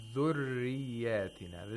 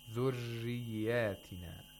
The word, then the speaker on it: ذُرِّيَّاتِنَا: You say ذُرِّيَّاتِنَا. This is the tapping way. You could also pronounce it like this. ذُرِّيَّاتِنَا. ذُرِّيَّاتِنَا. This is another way which is also correct.